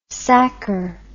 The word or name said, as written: sacchar